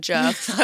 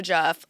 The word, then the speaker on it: Djerf: I don't even know if I'm pronouncing her name right, because I think I've seen her on Instagram and it's Djerf. So I think in my head, I've always just said Djerf, and I'm learning recently I've been saying it wrong, which I love to do with people's names.